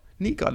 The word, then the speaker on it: Nygaard: Nygaard, I've given you the proper Danish, kind of proper Danish pronunciation this week.